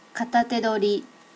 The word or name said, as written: katatedori